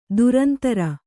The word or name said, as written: ಧುರಂಧುರಿ